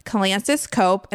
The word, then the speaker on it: Kalantzis-Cope: So we have Tamsyn, Gilbert, and Phillip, Kalantzis-Cope, and I'm going to ask them if I pronounce that correctly.